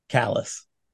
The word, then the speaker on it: Calais: And there's a there's a border town on the frontier there that's spelled C A L A I S. And I was just like, suspend everything, no book learning. How would you pronounce this? Callus.